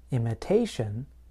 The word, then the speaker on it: imitation: But in the noun imitation, I place stress in the middle of the word, imitation.